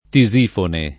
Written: Tisifone

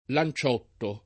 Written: Lanciotto

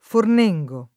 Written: Fornengo

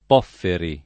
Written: Pofferi

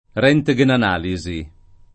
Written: röntgenanalisi

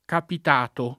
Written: capitato

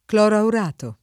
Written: cloroaurato

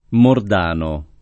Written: Mordano

